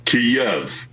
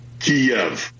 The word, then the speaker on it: Kyiv: So it is it Kyiv or is it Kyiv? Hmm. Now, the Russian pronunciation is Kyiv. The Ukrainian, closest approximation that we can do as non-Slavic speakers is Kyiv. So the Y ends at the first syllable.